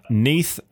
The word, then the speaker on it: Neath: Neath and Port Talbot Council have said, did I get it right? – Yes, you did.